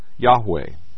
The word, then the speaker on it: Yahweh: The word Lord, in small caps, is the Hebrew personal, specific name of God, pronounced Yahweh.